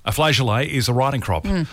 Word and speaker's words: flageolet: Now, there are a couple of different ways to pronounce this word today, Ali. – Okay. – I think I like the French-sounding one better. – Yep. – So, flageolet or flageolet.